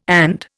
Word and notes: and: Indian

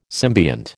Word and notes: symbiont: American English

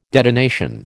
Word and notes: detonation: American English

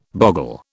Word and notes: boggle: British English

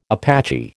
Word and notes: Apache: American English

